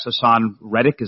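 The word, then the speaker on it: Haason Reddick: I know we've discussed in the past Hassan Reddick. Is that correct? That's how you pronounce his name. – Indeed.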